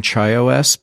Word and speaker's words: ChaiOS: But I'm calling ChaiOS because I really like chai. So it's spelled C-H-A-I uppercase O-S.